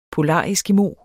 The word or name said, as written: polareskimo